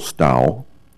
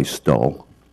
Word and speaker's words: Stowell: Harrison Marshall Stowell, rhymes with owl. Western Pennsylvania is known for having a lazy mouth. Here in Portland, I go by Stull.